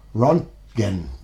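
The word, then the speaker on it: Röntgen: Start off with Rontgen by Kroko. – Yeah. – I pronounce it okay? – Yeah, that's like okay. Named after Rontgen, like an x-ray.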